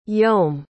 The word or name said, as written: Vyom